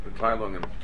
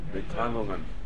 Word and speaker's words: Mitteilungen: Tell me, how do you pronounce this? The Mittling. – Mitteilungen. – There we go. The Mitteilungen. Again? – Mitteilungen.